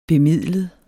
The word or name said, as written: bemidlet